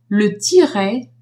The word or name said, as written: tiret